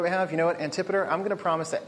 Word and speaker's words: Antipater: There's a weird story I alluded to last week where 3,000 Israelite soldiers, led by the general Antipater, Different people pronounce that differently. I will say Antipater.